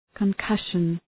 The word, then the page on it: concussion: kən’kʌʃən